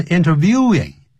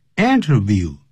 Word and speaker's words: interview: 记住不要念成 interviewing。 interview, 名词动词都是念成 interview。